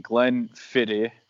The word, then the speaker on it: Glenfiddich: I am going to butcher it, but I am drinking Glenfiddich. It's Scottish, so apparently you're not supposed to say the CH at the end of it like that.